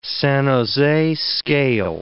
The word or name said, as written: San Jose scale